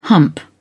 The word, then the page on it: hump: British English